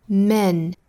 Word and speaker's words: men: 首先呢 ,men 应该读 men, men。里面这个元音呢就是小写的 e 的那个音标应该是发 a, 口型不能太大当然也不能过小不能发成了 men, 应该是 a, men, men。